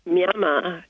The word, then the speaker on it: Myanmar: Well, for many people, it's still known as Burma, and the name that starts with an M that looks like it's called Myanmar is actually pronounced Myama in Burmese, and it means to grow stronger.